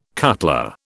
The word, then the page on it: cutler: British English